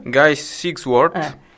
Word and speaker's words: Guy Sigsworth: E questo appunto è un brano che è stato scritto insieme a Guy, come si legge? Sigsworth. – Guy Sigsworth. Guy, ricordiamo il mitico Guy.